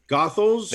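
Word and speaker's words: Goethals: Is that how you say it? – Gothels. The Gothels goes into Staten Island.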